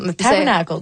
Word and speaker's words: Tabernacle: Comment on la prononce, cette salle-là? – What's it called? – Le Tabernacle. – Oh, the Tabernacle. Oh, the Tabernacle. Parfait.